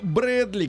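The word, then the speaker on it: Bradley: Скотт Брэдли, как Брэдли Купер, только да, по-другому да, да.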